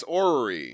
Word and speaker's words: orreries: Orreries. I don't know if I'm pronouncing that right. – Yes, orrery. – Orreries. – Right. Yes. – Orreries. – You're absolutely right. – They're called orreries.